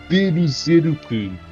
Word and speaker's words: Berserk: Eu queria fazer a recomendação de Berserk, ou para fazer a, a, pronúncia em, em japonês, Beruzeruku. Não falo japonês, como vocês já perceberam. Berserk, Berserk.